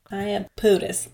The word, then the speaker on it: Iapetus: Iapetus.